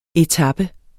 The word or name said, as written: etape